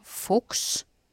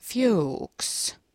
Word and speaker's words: Fuchs: Kirjoitetaan F-U-C-H-S, eli saksalaisittain se voisi lausua Fuchs, mutta sitten kun otetaan täältä Amerikka mukaan, niin lausutaan, tai tää lukee, että pronounced Fuchs, niin ei tule sitten ainakaan vahingossa sanottu mitään kirosanaa.